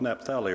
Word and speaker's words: Naphtali: You know, that's one of two of the 12 tribes of Israel, and I didn't know how to pronounce Naphtali, to get it right. Naphtali? Naphtali, so I looked it up. I think I'd always thought it was Naphtali or something. It's Naphtali.